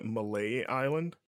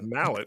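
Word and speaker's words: Mallet Island: Like, did you call it Malay Island? Is that actually what it is? I mean, always said Mallet. – That's how they say it in the game. That's what Trish calls it.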